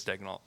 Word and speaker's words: Dagnault: I said, it's Dagnault. And he was like, what? What? I was like, yeah. I was like, that's what you're falling for the broadcast. The broadcast is saying it wrong. That's such a disservice to Mark that our own broadcast doesn't call him by the correct name.